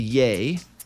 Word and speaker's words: Ye: Kanye has officially changed his name to Ye. So it's just Y-E now. – Yeah. – Pronounced Ye as in Kanye.